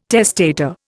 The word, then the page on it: testator: Indian